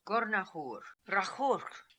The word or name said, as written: Gornahoor Rakhoorkh